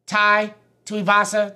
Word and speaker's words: Tai Tuivasa: We start this off with a heavyweight battle between Tai Tuivasa. Did I say that right, my Hawaiian brother? – Yep.